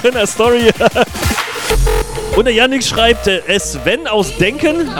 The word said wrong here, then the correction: Denkte: Habe ich das falsch ausgesprochen? Nee, Denkte. Groß Denkte.